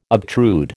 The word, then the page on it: obtrude: American English